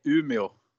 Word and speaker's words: Umeå: It sounded pretty good. You maybe should try to learn from more Swedish. maybe the correct. It was pretty good. In Sweden, we say Umeå. – Umeå. – Umeå.